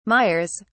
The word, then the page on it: mires: British English